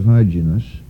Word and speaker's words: Hyginus: According to an ancient document cited by Irenaeus and preserved by Eusebius, he came to Rome during the pontificate of Hyginus, or however the hell, H-Y-G-I-N-U-S, Hyginus. I like that pronunciation because it makes me feel clean.